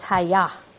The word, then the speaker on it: Hayah: Let there be, Hebrew, not Greek, is the Hebrew transliterated H-Y-H. And when I push the little button that pronounced it, it's Hayah.